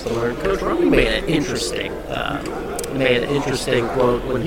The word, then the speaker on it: Rahne: But their coach, Rainey, did we pronounce that right? Coach Rainey? – Coach Rainey. – Yeah. – Ronnie. – Ronnie, that's right, like the Rock.